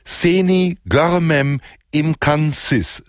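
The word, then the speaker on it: Seni Görmem İmkansız: Seni Görmem Imkansis. Sie haben mir gesagt, ich soll die letzte Silbe ganz besonders schwer betonen, aus Istanbul.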